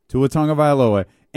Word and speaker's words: Tua Tagovailoa: Listen to him say it, Tua Tagovailoa, and then write it out your own way. – Yeah. – Tua Tagovailoa.